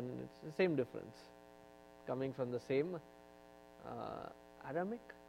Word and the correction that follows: Aramaic: Did I say it right? Aramaic.